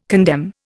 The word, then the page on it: condemn: Indian